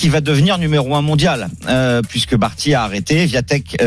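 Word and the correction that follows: Świątek: euh, d'ailleurs on dit Zviantek, ça s'écrit Zvia mais ça se prononce Zviantek La polonaise, elle a 20 ans.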